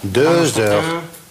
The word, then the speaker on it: Deux heures: При соединении, при связке читается как z. Deux heures.